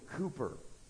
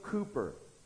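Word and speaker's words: Cowper: Now, if you looked at that name in your hymnal, you think, that's not how it's spelled. Okay, good, it's not pronounced phonetically. That's what the English do sometimes. William Cooper.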